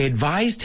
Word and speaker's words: advise: Y fijaos, to advise es el verbo. Suena como una Z, pura y dura. And advice, el sustantivo, que se escribe con una C, suena como una S.